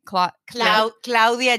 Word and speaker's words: Claudia: Claudia Janina Bucaro.